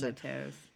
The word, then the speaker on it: Cosmatos: George Cosmatos? I don't know how you pronounce Cosmatos.